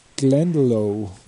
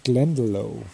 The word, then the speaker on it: Glendalough: Da sieht man Irland, Wicklow Mountains, Glendalough, glaube ich, spricht man das aus, Glendalough.